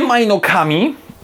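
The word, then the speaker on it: mynock: Nie majnokami, tak jak powiedzmy wymawia się mniej więcej w wersji angielskiej, tylko za mynockami.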